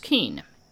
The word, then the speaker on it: Kuehn: This was the most common spelling I could find K U E H N but pronounced Keene.